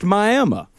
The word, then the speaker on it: Miami: There isn't a Hollywood, Texas, but there's a Miami, Texas, which is spelled Miami, but is pronounced Miami.